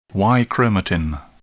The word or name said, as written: Y chromatin